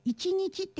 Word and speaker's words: 一日: タイトルが一日一生、一日って書くんだけど、読み方は一日と読むそうです。